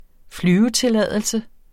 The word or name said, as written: flyvetilladelse